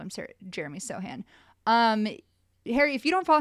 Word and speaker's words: Sochan: And I also recently found out it's pronounced Sochan and not Sochan, which is I was going to say that's not how you say it.